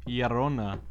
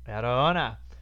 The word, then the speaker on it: Llorona: The Curse of La Llorona. Did I say that right? – Llorona. – Llorona.